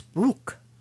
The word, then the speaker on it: Bruch: like Max Bruch. Am I saying that right? The German person here. – Yes.